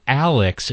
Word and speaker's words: Alix: And they're ready to release their fourth album called Alix. It's spelled differently. It's A-L-I-X.